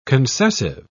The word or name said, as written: concessive